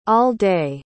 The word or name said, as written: all-day